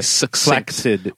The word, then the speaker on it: succinct: I'll go into that another day, but traditionally in in the languages that English is derived from, if you see a double C in anything, like succinct or flaccid, which most people are a little more familiar with, it's actually supposed to be pronounced as a K and an S sound afterward. – Okay, it's supposed to be succinct or flaccid. – Yeah. – But over time, people just become so lazy that just doing a double, like a S sound, is the acceptable sound. Anyway, nomenclature. It is a noun. And it is spelled N-O-M-E-N-C-L-A-T-U-R-E.